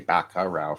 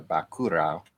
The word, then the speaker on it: Bacurau: But I always say it wrong. I always say Bacurau for some reason, but it's a Bacurau.